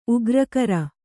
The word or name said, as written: ಉಗ್ರಕರ